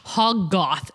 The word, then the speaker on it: Hoggoth: Agamotto, and Hoggoth. Gosh, Hoggoth. It's a literal pronunciation. Nice. Hoggoth. So Ashtar, or Ashtar, O-S-H-T-U-R, Ashtar. – O-S-H. – T-U-R.